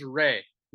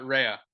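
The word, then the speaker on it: Rhea: Okay, yo, you got to say, her name's Ray, not Raya, by the way. – It's Ray? – It's just Ray, yeah.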